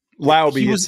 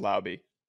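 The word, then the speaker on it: Laube: Or Lauby, however you, Lauby. He was on the first team. We confirmed it's pronounced Lauby.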